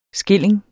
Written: skilling

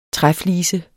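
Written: træflise

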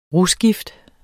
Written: rusgift

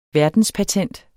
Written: verdenspatent

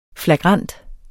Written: flagrant

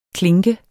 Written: klink

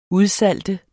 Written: udsalte

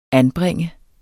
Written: anbringe

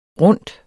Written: rundt

